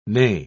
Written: neigh

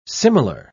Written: similar

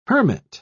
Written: hermit

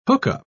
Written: hookup

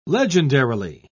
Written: legendarily

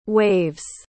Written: waves

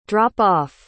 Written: drop-off

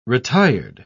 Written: retired

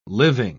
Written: living

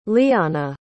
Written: lyanna

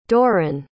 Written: dorrin

